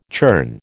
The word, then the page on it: churn: American English